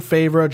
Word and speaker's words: Favreau: Is it John Favreau, John Favre? – Favre? – Favreau. – I like that.